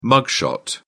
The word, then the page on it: mugshot: British English